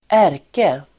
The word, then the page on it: ärke-: [²'är:ke-]